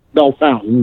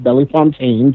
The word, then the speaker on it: Bellefontaine: They were based out of Logan County, Ohio, which is Bell Fountain, Ohio, because we refuse to, you know, actually pronounce anything with a French name actually French. Bell Fountain, not Bellefontaine, just like Versailles, Indiana.